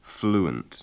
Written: fluent

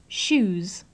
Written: shoes

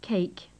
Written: cake

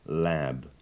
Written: lab

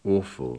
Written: awful

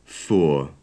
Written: for

